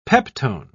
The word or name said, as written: peptone